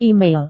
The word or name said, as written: email